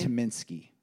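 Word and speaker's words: Tyminski: And it's Dan, how do you pronounce his last name? – Tominski.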